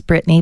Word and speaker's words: Brittni: So my name is Brittany, but it's spelled a little uniquely as B-R-I-T-T-N-I.